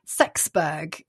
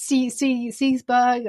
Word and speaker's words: Seaxburh: And we had the most amusing one was the one who's spelt S-E-A-X-B-U-R-H. – Right. – So I had a long discussion with my producer who was adamant it was going to be pronounced Sexburg, and I said, no, I don't think she's really called Sexburg. And we had this huge debate. I had to check with colleagues. No, it wasn't Sexburg. It's Seesburg or Seber something like that, but definitely not Sexburg.